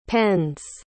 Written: pends

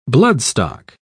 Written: bloodstock

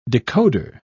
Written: decoder

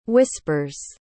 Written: whispers

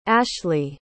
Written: ashli

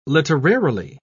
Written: literarily

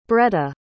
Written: bretta

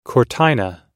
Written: cortina

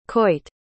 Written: coit

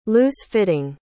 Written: loose-fitting

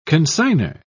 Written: consignor

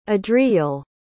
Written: adriyel

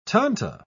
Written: tanto

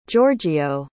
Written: giorgio

